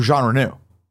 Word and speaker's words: Jean Reno: I think that there's issues with Onimusha because of the likeness of Gene Reno in Onimusha 3. – Okay. – And is that based on a real person? – Jean Reno? – Yeah. Oh, Jean Reno.